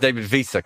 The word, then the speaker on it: Wiese: Have I pronounced David Wiese correctly? Yes, you have.